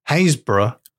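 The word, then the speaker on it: Happisburgh: The place names of Norfolk, one of the places where the big old dog turns up is called Haysborough. – Oh, yeah. – But it's spelled Happisburg. – Yeah. Like exactly like the name Habsburg.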